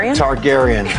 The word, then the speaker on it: Targaryen: Targaryen.